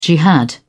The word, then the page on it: jihad: British English